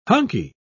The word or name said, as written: hunky